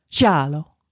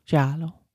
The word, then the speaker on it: giallo: Now that I know how to say it, giallo. – Mm-hmm. – Giallo.